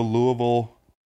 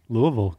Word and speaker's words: Louisville: I'm going to go Louisville And that's how you say it. – Louisville, good.